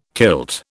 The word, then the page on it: kilt: British English